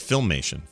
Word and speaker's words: Filmation: Femation. – Oh, I found it. Here it is. Oh, I'm sorry. I'm being told. I'm being told that's filmation. – Filmation. – Oh, okay. Makes sense. – Death filmation.